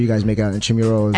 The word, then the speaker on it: Intramuros: How do you say it? Infirmary. – Oh, Intramuros. – Intramuros.